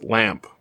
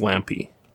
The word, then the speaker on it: Lampe: I always say it's Lamp, but it's not. It's Lampy, which is strange.